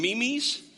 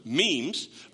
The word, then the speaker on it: memes: I used to call them memes, because I'm not very hip, apparently, and my children were quick to tell me, "Dad, you're saying that wrong." So, memes.